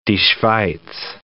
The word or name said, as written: die Schweiz